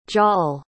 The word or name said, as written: jal